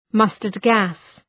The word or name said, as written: mustard-gas